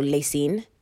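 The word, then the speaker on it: La Cygne: But my crime today is the way that they spell Lacyne, because it's spelled L-A and then C-Y-G-N-E. And I don't know how you get Lacyne from that. I literally had to type it out as Lay and Seen so that I can remember how to say it.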